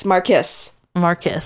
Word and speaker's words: Marquis: It's spelled like Marquis, but it is a name. I think it's Marquis. – No. It's Marquis. Marquis. Because I worked with a girl whose name was pronounced like that, and then I was trying to remember how she pronounced it.